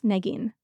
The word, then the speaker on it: Neagheen: It's equal emphasis on Nagin. Both syllables. Nagin.